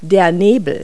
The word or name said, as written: fog